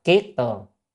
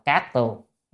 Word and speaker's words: kiết tường: Kiết tường hay cát tường đều được nha, nhưng mà trong nhà Phật thì chúng ta hay đọc kiết tường. À, còn ngoài đời người ta đọc là cát tường, à, thì chúng ta đọc nên đọc là kiết tường, à, ngoài đời để cho họ đọc cát tường.